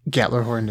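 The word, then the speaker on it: Gjallarhorn: Uh, by the way, I acknowledge that Gatlerhorn is the correct way of pronouncing it.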